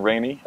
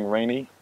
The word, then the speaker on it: Rainey: My Instagram is Iren Rainey, just my first and last name is spelled I-R-E-N, last name Rainey, spelled R-A-I-N-E-Y.